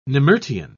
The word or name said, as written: nemertean